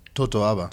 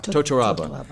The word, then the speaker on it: totoaba: the Tabora? – Totoaba. – Totoaba.